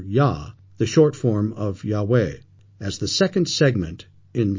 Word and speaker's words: Yahweh: Scholars believe the full name of the Lord, the Tetragrammaton, or four letters YHWH, is pronounced Yahweh.